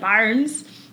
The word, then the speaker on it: bairn: Like, yeah, it spelled like BAIRN, I think, but like Barnes.